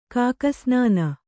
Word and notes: ಕಾಗೆಸ್ನಾನ: Kannada

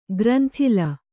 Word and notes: ಗ್ರಂಥಿಲ: granthila

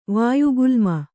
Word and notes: ವಾಯುಗುಲ್ಮ: Kannada

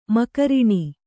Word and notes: ಮಕರಿಣಿ: Kannada

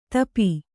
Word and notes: ತಾಪಿ: tāpi

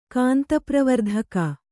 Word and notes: ಕಾಂತಪ್ರವರ್ಧಕ: kānta pravardhaka

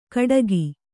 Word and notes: ಕಟಕಿ: Kannada

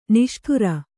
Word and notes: ನೇಷ್ಟಾರ: nēśṭāra

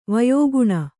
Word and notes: ವಯೋಗುಣ: vayōguṇa

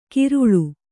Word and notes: ಕಿರುಳು: Kannada

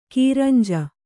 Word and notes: ಕುರಂಜಿ: Kannada

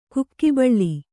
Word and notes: ಕಾಕುಬಲ: kākubala

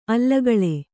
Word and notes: ಆಲಿಗೊಳ್: āligoḷ

